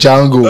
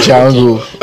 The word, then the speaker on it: Django: Django. – Are you sure? – Silent. – The literal D. – Silent D. – Oh, Django.